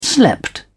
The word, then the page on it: slept: British English